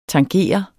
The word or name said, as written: tangere